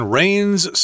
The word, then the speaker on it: reign: Now, here, reign is R E I G N, reign, and that is the uh, well, its pronunciation is the same as the stuff that falls out out of the sky in liquid form.